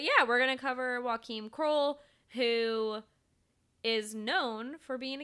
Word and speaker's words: Joachim Kroll: But the person that we're doing is Joachim Kroll.